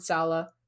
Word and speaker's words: Sale: It's literally pronounced just like you see it S A L E. Alex, you have a sale at your favorite store. That's his name, Rob Sale.